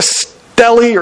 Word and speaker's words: stele: Now, another reason why people that hold to this late view, there's something called a Merneptah Stele or Steele, depends on who's pronouncing it. Steele, Stele, Stele, I've heard it multiple ways. Steele is probably the most appropriate.